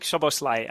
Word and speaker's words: Szoboszlai: Dominik Szoboszlai, I think. I don't know Hungarian, but I think that's how you say it. – It sounded pretty much perfect.